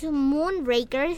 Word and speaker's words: Moonraker: Tenemos Moonraker, si así se pronuncia.